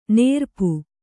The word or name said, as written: ನೆರಪು